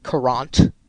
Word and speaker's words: Courant: We've got Alex Williams, who writes for Courant. That's how you pronounce it, Courant.